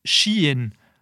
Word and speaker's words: Shein: die denken, ah, het gaat verschijn. S-H-E-I-N. Maar je spreekt het uit als Shein.